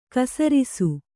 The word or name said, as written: ಕೈಸರಿಸ